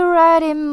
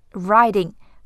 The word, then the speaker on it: right in: 我们都知道这是一个特别有名的酒，key 是一个长音，tequila sunrise，tequila sunrise，her body feel right in my hands，啦啦啦。好，这句话里面要注意的是，feel right in，riding，riding，right 和 in 连读成 riding，和前面的 fit 连在一起，feel right in，her body feel right in。